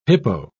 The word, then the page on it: hippo: British English